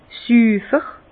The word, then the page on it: süfer: Bas-Rhin; Herrlisheim